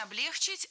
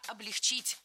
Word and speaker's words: облегчить: Не облегчить, а облегчить. Не облегчили, а облегчили. Не облегчит, а облегчит. В глаголе «облегчить» и во всех его формах ударение ставим всегда за корнем, а корень оставляем безударным.